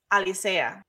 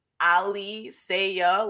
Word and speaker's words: Alicea: Ali Saya, like Muhammad Ali, and like you say a phrase, like Ali.